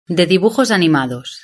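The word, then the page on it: de dibujos animados: Spain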